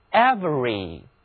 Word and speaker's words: every: 请看字母 v 和 r 之间的那个字母 e 现在不发音，听我读一遍 every，每一个的，全部的。